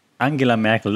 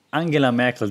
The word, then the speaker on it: Angela Merkel: And the era Angela Merkel, that's how you pronounce her name, by the way, not Angela, Angie, or Merkel, Angela Merkel.